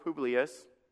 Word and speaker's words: Publius: And it came to pass, as the father of Publius,